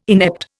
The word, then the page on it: inept: Indian